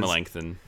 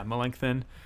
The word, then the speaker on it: Melanchthon: I never know how to say his. – Melanchthon. – Yeah, Melanchthon.